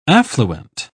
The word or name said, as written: affluent